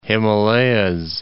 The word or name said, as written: Himalayas, the